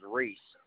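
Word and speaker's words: Rees: My last name is Reese, Romeo Echo Echo Sierra.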